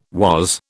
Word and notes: was: British English

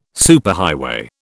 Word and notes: superhighway: British English

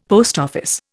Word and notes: PO: Indian

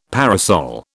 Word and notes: parasol: British English

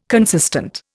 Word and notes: consistent: Indian

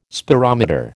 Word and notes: spirometer: American English

